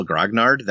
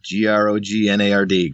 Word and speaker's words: Grognard: I said it pronounced it just like it's spelled, you know, Grognard, Grognard. It doesn't get any more simple than that.